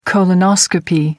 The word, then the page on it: colonoscopy: American English